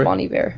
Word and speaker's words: Bon Iver: Yeah, it's Bon Iver.